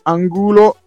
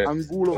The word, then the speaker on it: Angulo: Farinos, Mendieta, Chili Gonzalez, Gerard, Gerard, non so come si pronunci, Miguel Angel Angulo Scusate la...